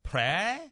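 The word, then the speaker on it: Phrae: She taught up in a little town called Prey. That's how I pronounce it, P-R-P. P-H-R-A-E. But the way the Thai is pronounced, it has about four different tones in there, so it's more like pra.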